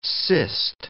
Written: cist

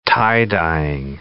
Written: tie-dyeing

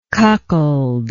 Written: cuckoldly

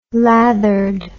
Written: lathered

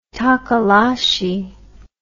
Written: tokoloshe

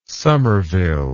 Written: somerville

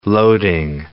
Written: loading

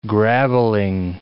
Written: grabbling

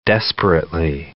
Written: desperately

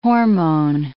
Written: hormone

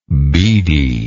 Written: beadiness

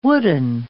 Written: wooden

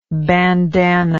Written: bandannaed

